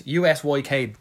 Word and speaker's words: Usyk: And also, how do you pronounce this? USYK, the boxer. – Usyk. – Usyk, there you go, is taking on Tony Bellew for all the belts in the Manchester Arena.